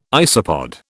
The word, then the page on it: isopod: British English